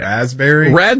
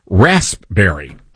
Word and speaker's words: raspberry: Raspberry? – No, that's not how you say it. It's rasp. Raspberry. – Oh, yeah. – There's a P there.